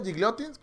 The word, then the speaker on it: Gigliotti: Y después en el minuto 84, este Gigliotti, ¿cómo se pronuncia? Gigliotti, creo que se lo pronuncia bien.